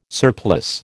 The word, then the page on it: surplice: American English